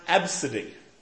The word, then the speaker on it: Abcde: spelled A-B-C-D-E. And it was pronounced Absidy.